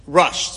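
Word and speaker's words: Rush (ראש): Rabbeinu Asher ben Yechiel, known as the Rush, spelled like Rosh, people say the Rush, Reish Aleph Shin.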